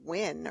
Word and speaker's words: Nguyen: N-G-U-Y-E-N, and I happen to know that's pronounced Win or something like that, which is not at all what it looks like on the written page.